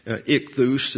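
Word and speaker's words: Ichthus (ἰχθύς): Ichthus is the way it's pronounced. The chi, it looks like an English X, but it's a transliterated CH.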